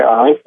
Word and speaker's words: Onint: And they can also just Google my name, Troy Inint, O-N-I-N-T,